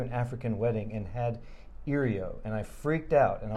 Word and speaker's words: IRIO: It was called IRIO, I-R-I-O.